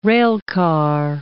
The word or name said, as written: railcar